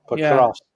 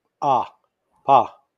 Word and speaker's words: Pokrovsk: Pokrovsk. Yeah, when you look at it, it's spelled, we see it as P-O. – Yeah. – And we normally will pronounce things like Po. But in Russian, it's pronounced Pokrovsk.